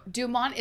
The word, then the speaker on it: Dumont: Did you pronounce it Dumort? – I did. – Am I saying that incorrectly? Dumont? – No, Dumont is the correct pronunciation. – Oh, right. – Dumort is how it's changed.